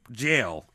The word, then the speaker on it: gaol: Anyway, he was caught doing this, and in 1777, William was sent to the Litchfield Gaol. Is that how you pronounce that? G-A-O-L? – G-A-O-L is pronounced jail. – Jail? – Yes. – Spelled wrong? – No, that's the UK version of spelling jail.